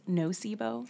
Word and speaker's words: nocebo: and do you pronounce this nocebo? – Yeah.